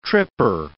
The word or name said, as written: tripper